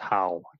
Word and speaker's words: Howe: Yeah, so it's pronounced Howe too. Yeah, I don't know if you Kelly Howe. – Yeah, we got that right.